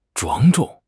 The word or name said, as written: 庄重